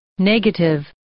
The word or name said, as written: negative